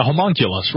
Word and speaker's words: homunculus: Yeah, a human nucleus, or what are they? What's the other type? Human nucleus? How do you say it? – A homunculus, right. – Homunculus, yeah. There we go.